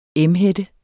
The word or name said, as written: emhætte